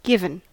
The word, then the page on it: given: American English